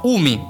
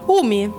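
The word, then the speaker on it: umi (海): Letteralmente si pronuncia umi, mare. – Umi. – U-M-I.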